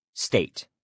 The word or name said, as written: state